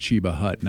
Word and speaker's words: Cheba Hut: Somebody said, Natalie, it is pronounced Cheba Hut, not Cheba.